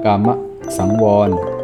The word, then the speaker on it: กามสังวร: กามสังวร